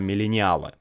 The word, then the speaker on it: миллениалы: я обычно говорю миллениалы, но я знаю, что сейчас в русском языке ударение на «а», миллениалы.